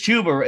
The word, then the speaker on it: Chuba: No, it's Chuba. It is Chuba though, right? – It's Chuba, not Chuba.